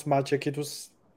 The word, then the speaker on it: Maciek: I did practice it, and then I've forgotten what I practice. Palmowski. – That's perfect, really. – Is it? – Yes, and when I heard how you pronounce Maciek, I was really impressed, really. I thought that you were practicing like the whole week.